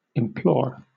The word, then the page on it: implore: British English